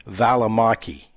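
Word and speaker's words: Välimäki: If you remember last week, we were talking about golf and trying to get that Valimaki or whatever his name was. Well, the pronunciation on that, his name is Sammy Valimaki.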